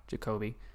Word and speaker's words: Jakobi: But it's the official Jacoby, J-A-K-O-B-I, Jacoby.